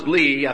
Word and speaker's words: Lee: Or as Thomas Lee, I think I pronounced it Lay last Sunday, but Thomas Lee describes it this way, quote, "People follow the practices of the world while pretending to hold to God."